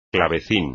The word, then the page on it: clavecín: espagnol